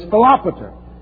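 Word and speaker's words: Philopator: 11th verse describes what historians call the Battle of Raphia, R-A-P-H-I-A. The successor to Euergetes is Philopater. These are names that kill you. Phonetically, Philopater. But it's Philopater.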